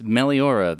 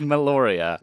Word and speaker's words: Meliora: Yes, Meliora, that one that I have been pronouncing right the entire time on the podcast, not been pronouncing as Meloria. I swear to God. No, that makes sense, to be fair. Meliora, which is the correct name for that album, as everyone knows.